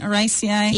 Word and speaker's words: Araceae: Araceae? – Yeah.